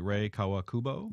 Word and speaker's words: Rei Kawakubo: You write about the fashion designer, and make sure I pronounce this correctly, Rei Kawakubo. – Yep, that's right.